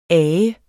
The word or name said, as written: ager